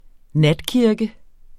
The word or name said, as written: natkirke